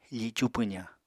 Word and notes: ficu pwzañ: apatani

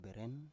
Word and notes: beren: Biak

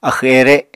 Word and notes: axw-ere: apatani

